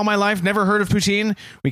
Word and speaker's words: poutine: Saying poutine is not.